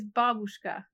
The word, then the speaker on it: babushka: That's not how you pronounce it. Do you know that? – How do you pronounce it? – It's babushka. – Babushka. – The emphasis is on the first part.